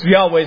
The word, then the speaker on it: Yahweh: All right, I'm going to use Yahweh as my pronunciation.